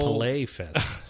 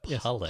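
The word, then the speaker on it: PaleyFest: So moving right along, next item here, The Walking Dead will be at the 2011 Pally Fest. Or Pally Fest? I don't know how to pronounce that. I don't know. – Palais Fest. – Sorry, Palais Fest. That's like Play Fest, but Palais.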